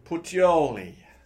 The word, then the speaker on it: Puteoli: That means they've done the toe of Italy, and now they're heading north to a major port called Puteoli. You got to say it like an Italian might.